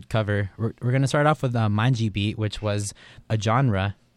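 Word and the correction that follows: Mangue: Am I saying it right? – Mangi Beat.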